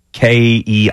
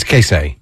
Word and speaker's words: Keisei: Yeah, Tominaga, but it's I don't know how to pronounce his first name. It's Keisei. – He's Kasei. – Kasei Tominaga.